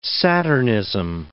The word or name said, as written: saturnism